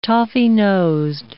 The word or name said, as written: toffee-nosed